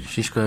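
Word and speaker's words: Szyszko: Şişko adı. Doğru telaffuz. Adı Şişko adam. – Şişko evet.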